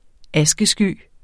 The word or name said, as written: askesky